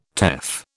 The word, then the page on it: teff: British English